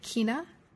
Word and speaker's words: Quina: Matt, tell me if I'm getting this right. Quina? – Right? Yeah, that's correct.